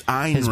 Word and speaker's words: Ayn: It's Ayn Rand, Scott. Rhymes with mine.